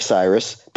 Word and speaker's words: Sirus: They do it with my name, which is Dave Cyrus, but it's spelled S-I-R-U-S.